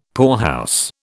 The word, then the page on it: poorhouse: British English